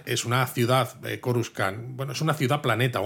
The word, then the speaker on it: Coruscant: Se ve Coruscant, aunque bueno, aquí en inglés lo llaman Coruscant. ¿Cómo lo tengo que pronunciar Coruscant. yo esto? – Coruscant, vale, pues vemos una Coruscant súper gris.